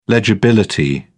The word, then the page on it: legibility: British English